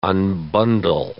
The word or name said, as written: unbundle